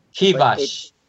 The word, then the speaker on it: Kivash: It's like car wash. Kivash.